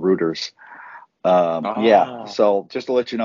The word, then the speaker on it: Reuters: Reuters, and you said Reuters last week.